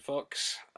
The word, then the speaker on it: Fuchs: And then, of course, there is the screenplay by Jason Fox, and that is his actual name, and it is spelt F-U-C-H-S.